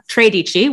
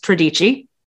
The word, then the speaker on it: tredici: Um, in terms of her super exclusive retreats, um, she has these things that she like to call uh, tredici, which is a mispronunciation of the Italian word for 13. It's tredici. God damn it. She's putting the emphasis on the wrong syllable.